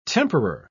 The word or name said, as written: temperer